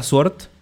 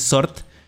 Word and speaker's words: SWORD: O sea, nunca habíamos visto a Sword, a Sort, que la correcta pronunciación es Sort,